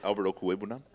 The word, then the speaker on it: Okwuegbunam: Yeah, Albert Okwuegbunam. – That's great pronunciation.